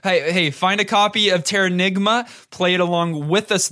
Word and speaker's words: Terranigma: Yeah, Terranigma, yeah, that's it.